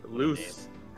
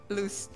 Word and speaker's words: Luz: Loose, like light. – Loose, yeah, light.